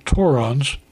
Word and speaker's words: Torons: The members discuss the Torons, rhymes with morons, a reference to the tourists that continue to visit despite West Texas emerging as a hotspot.